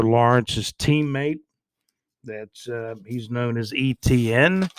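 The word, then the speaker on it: Etienne: I wrote down ETN. That's kind of how you pronounce his name, but it's, you know, it's spelled out Travis ETN. I believe it's spelled ETINNE, but I wrote it out kind of phonetically as ETN, like ET and then an N.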